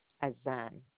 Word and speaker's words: Azan: My name is Winsome Azan.